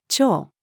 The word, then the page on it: 蝶: female